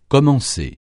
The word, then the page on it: commencer: francais